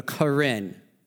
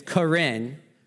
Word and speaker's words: Karen: Karen. It's spelled Karen, but it's not Karen.